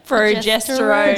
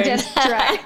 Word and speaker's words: progesterone: Progesterone. Progesterone. Progesterone.